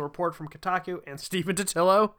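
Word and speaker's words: Totilo: So this one comes from Kotaku by Steven Totillo. – I believe it's pronounced tortilla. – It's definitely not. There's no R in his name.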